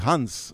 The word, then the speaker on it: Hans: Alors, Jean est prononcé Hans avec un H euh, en norvégien et en danois, ce qui d'ailleurs n'est pas très loin, pensons-y, du fameux Han hein.